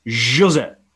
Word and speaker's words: José: I hate when people pronounce my name wrong. It's José. Jose. Ah, okay. It's not Jose. Jose. Jose.